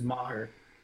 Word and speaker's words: Maher: His kicker, Brandon Maher, or whatever his name is, Brett Maher, something like that. Not Brandon. I always call him Brandon. – Man, of course you do. – No, but his name is Maher. That's literally how they say it.